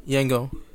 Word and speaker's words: Yangon: Yangon.